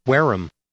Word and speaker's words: Wareham: The town is spelled W-A-R-E-H-A-M. And if you go to the Merriam-Webster Online Dictionary, you can click and hear this pronunciation for the town in southeast Massachusetts. – Wareham. – Wareham. No audible H.